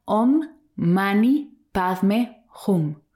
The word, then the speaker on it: Om Mani Padme Hum: Te voy a invitar ahora a repetir conmigo un hermoso mantra que se pronuncia Om Mani Padme Hum,